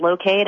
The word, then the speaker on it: Loc-Aid: It's pronounced Locate, and it's LOC-AID.